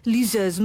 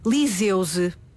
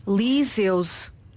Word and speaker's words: liseuse: Esta palavra provém do francês e em francês seguramente pronunciar-se-á liseuse, mas em português pronuncia-se liseuse, liseuse.